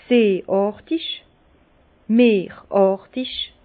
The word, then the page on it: seeàrtig, meeràrtig: Bas-Rhin; Herrlisheim